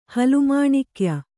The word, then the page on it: ಹಲುಮಾಣಿಕ್ಯ: halu māṇikya